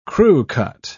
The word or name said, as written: crewcut